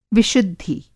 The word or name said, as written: विशुद्धि